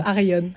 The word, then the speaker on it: Arion: Ah, je le dis à l'espagnol, moi, n'importe quoi. Ça s'écrit Arion. Arion. Euh, elle le prononce Arion.